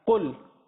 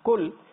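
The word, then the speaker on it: Qul (قل): The letter qaf with the two dots on top. This is pronounced with the full mouth. It's pronounced in a thick tone of voice. Instead of saying qul, if somebody just says the normal kaf, qul, he's changed the meaning.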